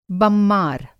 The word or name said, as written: बममार